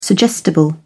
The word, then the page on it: suggestible: British English